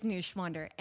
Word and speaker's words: Neuschwander: It is Neuschwander. Neuschwander.